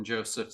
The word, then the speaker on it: Joseph: Basically, I'm on pretty much any of the social medias as Kevin Joseph CMX. So the last name is spelled J-O-S-E-P-H.